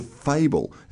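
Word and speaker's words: Phable: called Fable. It's spelled P-H-A-B-L-E, P-H-A-B-L-E. Could you explain to us what that basically involves, Nadia?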